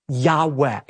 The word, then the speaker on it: Yahweh: What we actually have there in the original manuscripts are the letters Yud, He, Vav, He, which are God's personal name that most Semitic scholars believe is pronounced a breathy Yahweh.